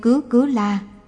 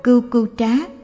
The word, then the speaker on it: Cứu cứu la: Cứu cứu la phiên âm từ Phạn ngữ là cút cút ti ca, cũng đọc là cưu cưu trá, chỉ con gà, vì nhân theo tiếng kêu của gà mà tiếng Phạn gọi là cứu cứu la.